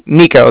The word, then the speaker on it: Niko: Nico. That's spelled N-I-K-O.